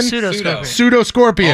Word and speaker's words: pseudoscorpion: P-S-E-U-D-O. How do you say it? Pseudoscorpion? – Pseudoscorpion.